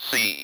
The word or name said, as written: sea